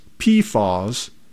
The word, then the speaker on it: PFAS: Federal environmental officials host a webinar this week to discuss the Environmental Protection Agency's proposed new limits on dangerous substances called PFAS, pronounced PFAS, in the state's drinking water.